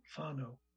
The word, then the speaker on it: whānau: And one of the words that they use is whānau, W-H, which is F in Maori pronunciation, F, W-H-A-N-A-U, whānau.